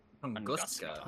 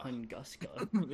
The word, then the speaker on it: Tunguska: But same with the how do you say it? – Tunguska. – Tunguska. – You have to say it like that. – Tunguska.